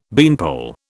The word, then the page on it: beanpole: British English